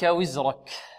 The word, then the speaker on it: وزرك: عنك وزرك وزرك الراء مفخمة، لأن لماذا فخمت الراء؟ لأنها مفتوحة، ونحن قلنا الراء المفتوحة والمضمومة مفخمة والراء المكسورة.